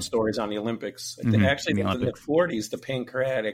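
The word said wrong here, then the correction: pankration: I can't pronounce bad, but the Pancration.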